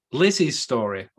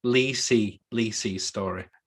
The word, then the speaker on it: Lisey's Story: First on my list this week, one of two, Lizzie's Story on Apple TV. Or is it? Because it's actually pronounced Lisey's Story.